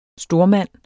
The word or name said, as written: STORMANDENS